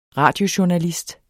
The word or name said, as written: radiojournalist